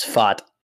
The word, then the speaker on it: Pfaadt: I think that's how you say his name is Pfaadt. – He spells it P-F-A-A-D-T.